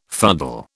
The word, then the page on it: fuddle: British English